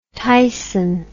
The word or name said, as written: tyson